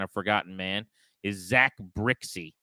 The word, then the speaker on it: Brzykcy: Brixey, that's how you say his name. It's spelled B-R-Z-Y-K-C-Y. So it looks like... – Can I buy it now? – Brizgich? I don't even know how you would pronounce that based on what it looks like, but it's Zach Brixey.